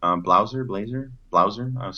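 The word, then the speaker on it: Blaser: One is Missing Microbes by Martin Blazer. Blauser. Sorry, Martin, got your name wrong there.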